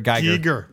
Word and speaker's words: Giger: Geiger. Is it Giger? Is that the pronunciation? – Yeah, it's Geiger Counter.